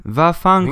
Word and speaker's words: Vaffanculo: V-A-N. – Or no. Okay, sorry. Erase the N. – All right. – It's V-A-F-F. A-N. C-U-L-O. – C-U what? – C-U-L-O. I'm pretty sure that's how you spell it. – C-U-L-O.